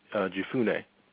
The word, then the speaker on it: Jephunneh: Two of the men had explored the land, Joshua son of Nun and Caleb son of, how do you pronounce that, Oshel? Jephunneh. – I believe it's, Jephunneh. Jephunneh, I believe that's pronounced it.